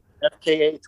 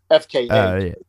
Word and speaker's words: FKA: I didn't hear you say the A. I thought you just said FK. It's FKA.